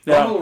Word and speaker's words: Bumble: Bumble rhymes with bungle.